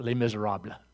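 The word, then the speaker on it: Les Misérables: That's the real French pronunciation, Les Miserables.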